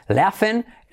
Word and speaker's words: laughing: Porque tenemos sobre todo, bueno, ya sabéis, en este caso esta palabra GH se pronuncia como una F, por alguna razón que se nos escapa. Laughing.